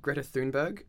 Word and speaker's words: Greta Thunberg: But following the worldwide climate strike, Greta Thunberg, I think I'm pronouncing that right. – I think you did pretty well, actually.